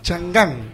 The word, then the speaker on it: Chang'an: No sé si está bien pronunciado. Chang'an, ¿puede ser la marca? – A ver, permíteme. – Chantan. – Chang'an. – Sí, Y el nombre Chang'an directamente.